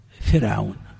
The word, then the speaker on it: فرعون: يخطئ كثير من الناس فيفخم راء فرعون ويرقق راء مريم، يا مريم، ها، ويرقق راء مريم فيقول يا مريم، يا مريم اقنتي لربك. والصواب العكس فيهما، الصواب العكس فيهما.